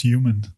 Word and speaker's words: HVMN: ik moet het uitspreken als human. Maar... HVMN noemen we het.